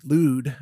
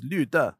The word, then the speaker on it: Lüde: which is spelled L-U-D-E in English, lewd, I guess, but I think in Chinese it's Lüde.